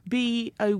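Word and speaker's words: Bó: Firstly, Lee, before we get into this, B-O with an accent. – It's a boe. – Bo. Like Spanish, so the accent is on the O.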